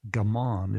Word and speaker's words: gaman: First, let me just say, at least with my pal, there's a word, a Japanese word for endure, and it's gaman. Is that the pronunciation, Norm? – Right.